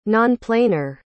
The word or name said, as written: nonplanar